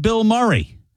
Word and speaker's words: Bilmuri: You see, it's pronounced Bill Murray, but it's spelled B-I-L-M-U-R-I, like Bill Murray. But trust me when I tell you, it is Bill Murray, but not the Bill Murray.